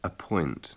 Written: appoint

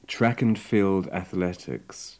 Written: track and field athletics

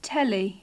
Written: telly

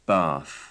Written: bath

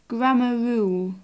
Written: grammar rule

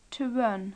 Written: to run

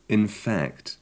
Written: in fact

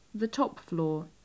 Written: the top floor

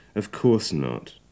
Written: of course not

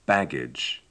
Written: baggage

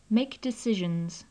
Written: make decisions

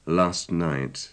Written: last night

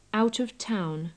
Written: out of town

